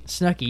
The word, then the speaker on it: Snooki: Snooki, yeah, yeah. She rhymes with cookie, dude.